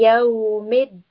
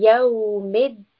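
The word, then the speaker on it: Yawmiddin (يوم الدين): Maliki, Maliki Yawmiddin. And here dal, we have a shadda, so we will pronounce the letter dal twice. Yawmiddin, Maliki Yawmiddin.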